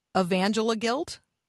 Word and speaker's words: Evangeliguilt: It's a made up term. So you can do what you want with it. I say evangeliguilt, but yeah. – That's much better. Evangeliguilt. – Yeah, it just, Yes. – Evangelical guilt. Sort of like rhymes with evangelical,